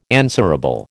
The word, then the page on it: answerable: American English